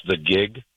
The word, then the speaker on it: Kieg: Pronounce the gig, but it's K-I-E-G. – Okay, the gig, but K-I-E-G is how you spell it.